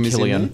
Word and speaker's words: Cillian: Cillian Murphy, or Cillian Murphy, how you pronounce him, Cillian.